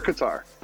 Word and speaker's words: Qatar: How do you say? Is it Qatar or Qatar? – Qatar.